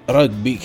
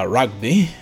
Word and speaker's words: rugby: Il rugby, che in realtà si pronuncia rugby, ma io continuerò a pronunciarlo sbagliato, perdonatemi, è una questione di abitudine.